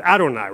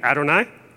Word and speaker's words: Adonai: Instead, they would say the Hebrew word Adonai. Repeat after me, Adonai. This is the Hebrew word for the word Lord. Okay? Doing okay so far? So instead of saying Yahweh, they would say Adonai.